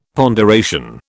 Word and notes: ponderation: British English

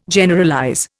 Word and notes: Generalize: Indian

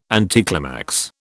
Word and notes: anticlimax: British English

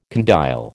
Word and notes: condyle: American English

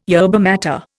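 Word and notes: yerba maté: Indian

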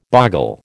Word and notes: boggle: American English

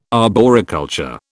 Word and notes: arboriculture: British English

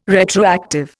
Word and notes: retroactive: Indian

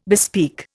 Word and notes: bespeak: Indian

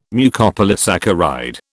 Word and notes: Mucopolysaccharide: British English

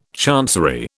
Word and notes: chancery: British English

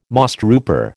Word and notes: mosstrooper: American English

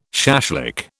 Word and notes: shashlik: British English

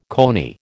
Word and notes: coney: American English

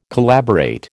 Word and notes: collaborate: American English

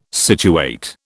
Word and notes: situate: British English

